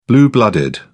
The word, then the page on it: blue blooded: British English